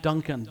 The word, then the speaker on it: Duncan: I bear my family's name, last name Duncan, D-U-N-C-A-N, the right way to spell it.